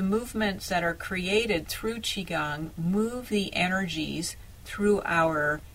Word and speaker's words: Qigong: And one of the things that I love to do that I work with my clients, and that is using something called Qigong, spelled Q-I-G-O-N-G. Sometimes it's spelled C-H-I-G-O-N-G.